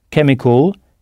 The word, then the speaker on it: chemical: Chemical, qui s'écrit C-H-E-M-I-C-A-L. Donc l'ensemble C-H-E du début du mot se prononce ke, chemical.